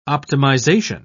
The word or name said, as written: optimization